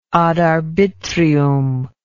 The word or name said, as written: ad arbitrium